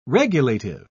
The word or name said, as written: regulative